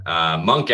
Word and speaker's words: Munq: Monk actually, it's spelled M-U-N-Q, but it's a rendition of M-O-N-K.